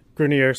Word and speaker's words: Grenier: I had Grenier, sorry for the mispronunciation earlier. Don't know where I got Grenier from.